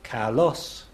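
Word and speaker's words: kalos (καλός): So a word like beautiful in Greek, kalos, the voice rises on the second syllable.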